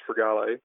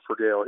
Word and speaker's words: Frogale: If we were in Italy, it'd be pronounced Frogale, but we do Frogale here in the U.S., so you got it right.